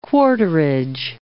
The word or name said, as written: quarterage